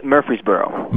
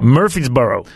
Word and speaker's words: Murfreesboro: I know it's in Tennessee, but how do you pronounce the town you're from? – It's Murfreesboro. – Murfreesboro. – That's it.